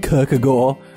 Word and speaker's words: Kierkegaard: How about Niccolo Machiavelli, René Descartes, Epictetus, Søren Kierkegaard? – I believe it's pronounced Kierkegaard, but yeah, that sounds like my cup of tea.